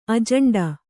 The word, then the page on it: ಅಜಾಂಡ: ajāṇḍa